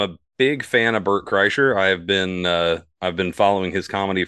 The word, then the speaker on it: Kreischer: Burt Kreischer? – Kreischer. – Sorry, I butcher his name.